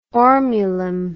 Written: ormulum

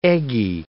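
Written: egis

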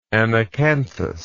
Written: anacanthous

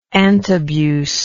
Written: antabuse

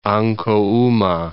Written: Ancohuma

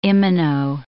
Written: imino

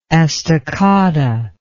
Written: estocada

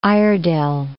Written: Iredell

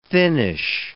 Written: thinnish

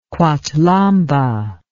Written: quathlamba

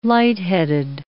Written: light-headed

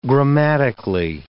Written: grammatically

